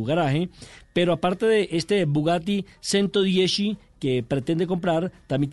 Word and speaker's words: Bugatti: Pues bien, ahora hay que decir que es uno de los 10 hombres que está buscando el famoso Bugatti 110, ¿así se pronuncia? Ajá.